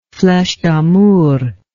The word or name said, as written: fleches damour